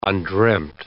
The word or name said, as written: undreamed